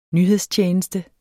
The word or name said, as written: NYHEDSTJENESTE